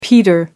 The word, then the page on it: peter out: American English